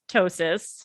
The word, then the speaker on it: ptosis: She also tells us, and this is going to be the start of a big, long storyline for Jennifer, that she has ptosis. I believe that's how you pronounce it.